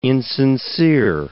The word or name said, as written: insincere